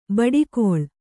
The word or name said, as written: ಬಡಕಲು